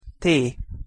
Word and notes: t: Hungarian

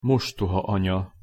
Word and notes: mostohaanya: Hungarian

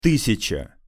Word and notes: tysjaca: Russian

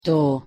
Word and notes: to: Hungarian

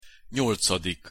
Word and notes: nyolcadik: Hungarian

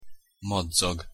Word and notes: madzag: Hungarian

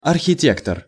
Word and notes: arhitektor: Russian

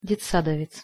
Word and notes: detsadovec: Russian

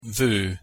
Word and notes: vo: Hungarian

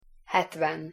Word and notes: hetven: Hungarian

